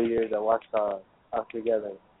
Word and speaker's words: Ouachita: I guess you'd be the pride of Ouachita Baptist University, correct? – Yes, sir.